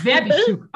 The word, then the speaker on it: Verbishchuk: Werbistück, okay, sehr gut, sehr gut.